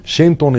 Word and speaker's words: Synthon: Synthon is spelled S-Y-N-T-H-O-N. T-H-O-N. Synthon. – C-H-O-N.